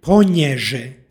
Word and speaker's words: ponježe: Pročitali smo veznik ponježe sa nj. Dakle, ponježe.